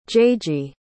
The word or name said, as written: jaygee